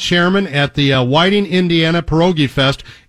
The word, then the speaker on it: pierogi: You are pronouncing pierogi correctly. Now, there are a lot of different spellings, and there's a lot of different pronunciations, and some people roll their tongue, pierogi, but pierogi is fine.